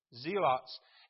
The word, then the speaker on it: Zealots: Zealots.